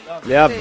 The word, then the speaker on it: Le Havre: Le tengo que preguntar a Asunción si se pronuncia así. Le Havre. – Más o menos. – Le Havre.